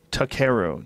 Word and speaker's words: Takeru: Takeru. T-A-K-E-R-U.